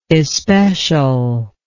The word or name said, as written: especialness